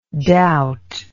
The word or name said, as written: doubtingness